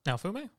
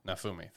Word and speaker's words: Naofumi: Nafo, um, how you pronounce his name? – Naofumi. – Naofumi, thank you.